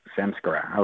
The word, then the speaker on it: samskara: Samskara? – Yes, and, samskara.